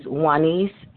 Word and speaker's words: Juanice: My name is Juanice, N as in Nancy.